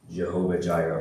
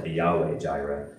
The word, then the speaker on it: Jehovah Jireh: God called that place Jehovah Jireh, or Yahweh Jireh. The Hebrew name for God can be pronounced two different ways.